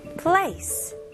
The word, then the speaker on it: place: 영어로는 그냥 이름절로 하셔야 돼요. 그래서 여기 있는 소리들 place 연결해서 이름절 place.